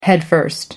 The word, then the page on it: headfirst: British English